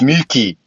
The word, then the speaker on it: Myooki: It's Muki. So it's Muki. It's not even that hard, actually, but it's spelled M-Y-O-O-K-I. That's why I was like...